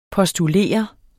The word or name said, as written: postulere